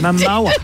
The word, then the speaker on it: Momoa: I was sure you said his name wrong, but you Momoa. Think of Moa, mowing your lawn.